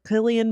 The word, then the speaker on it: Cillian: And now I'm going to go watch Oppenheimer and Clint, Clint Cillian Murphy, I think is how you say his name.